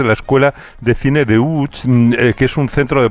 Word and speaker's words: Łódź: Aunque se pronuncia Łódź, se escribe Lodz, por si alguien lo busca. – Lodz, casi no tiene nada que ver la pronunciación.